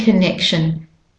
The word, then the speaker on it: connection: So the O in connection is a schwa sound, and the main stress syllable is the neck syllable. Connection. You can hear the drum goes dum on the neck.